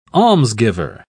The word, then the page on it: almsgiver: British English